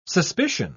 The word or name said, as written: suspicion